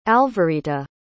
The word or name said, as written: alvarita